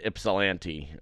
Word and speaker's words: Ypsilanti: Yeah, also, apparently you don't pronounce the Y at all in Ypsilanti.